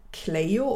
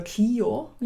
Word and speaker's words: Clio: Cleo oder Clio ja. – ist eher die lateinische Variante, die man kennt.